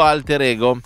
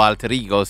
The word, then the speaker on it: Alter Ego: È uscito Alter Ego, o Alter Ego se preferite pronunciarlo all'inglese, anche se stiamo parlando del primo disco solista attesissimo di Lisa.